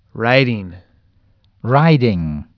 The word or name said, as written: riding